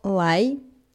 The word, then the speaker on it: 来: На очереди у нас интересный глагол «лай», «лай», вторым тоном «лай», Давайте рассмотрим сначала его произношение, а впоследствии уже его перевод и использование. Лай произносится вторым тоном, то есть мы коротко спрашиваем, переспрашиваем «да», «лай», «лай».